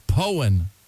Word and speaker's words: Poyen: Again, it's Powen, Arkansas, P-O-Y-E-N.